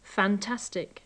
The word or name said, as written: fantastic